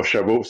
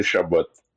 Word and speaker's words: Shabbat: Le T à la fin de Shabbat n'est pas silencieux. Donc, on le prononce. C'est pas Shabbat, c'est Shabbat.